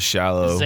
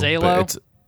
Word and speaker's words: Xalo: My brain went to shallow. – Zalo. – It's Zalo, yeah, maybe.